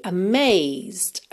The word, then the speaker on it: amazed: I'm sure you'll be amazed. Amazed. Amazed. Você basicamente vai ouvir mesmo até o Z, tá? Porque a letra E não é pronunciada.